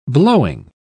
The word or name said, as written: blowing